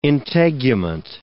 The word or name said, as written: integument